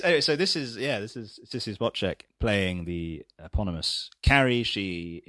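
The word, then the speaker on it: Spacek: Spotcheck, yes. – Is it Spotcheck? Have I got that right? – I think it's supposed to be pronounced Spotcheck.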